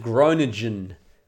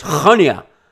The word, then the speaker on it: Groningen: That would be pronounced Groningen in English, but it's Groningen in Dutch.